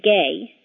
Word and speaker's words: Gae: My first name, Gay, is spelled G-A-E, and when my parents named me that, its main meaning was just happy.